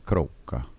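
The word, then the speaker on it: Kråka: And that's spelled K-R-A with a little O over the A. K-A, Kroka.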